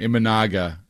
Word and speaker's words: Imanaga: Because, like, looking at the list, it doesn't seem like there are too many other guys that would command like a 5-6-7 year deal. – Yeah, this guy, and I'm going to mispronounce his name, Imanaga. I actually think I pronounced it. I nailed it, actually.